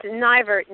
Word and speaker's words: Neivert: It's Neivert. Nye like pie.